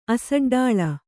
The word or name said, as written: ಅಸಡ್ಡಾಳ